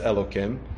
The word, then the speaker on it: Elokim: Vayomar, and he says, well, the way we would read this is right, if you were the way we would pronounce it is Hashem Elokim. Right? When we read Navi, when we have two names of Hashem spelled like that, we would pronounce the first one as Adnas, as Adoshem, and the second one, which if it was alone, we would pronounce it as Adoshem. We pronounce it as Elokim.